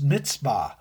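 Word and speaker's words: mitzvah (מצוה): The word's pronounced mitzvah, which means command.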